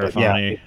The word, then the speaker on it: Sirianni: I can't pronounce it. – Yeah, Sirianni.